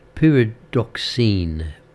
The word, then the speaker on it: pyridoxine: Vitamin B6, pyridoxin. It's a bit of a pyridoxin. That's the correct pronunciation of it. Pyridoxine. Pyridoxine. Yeah, cause that's better.